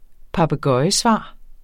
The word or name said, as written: papegøjesvar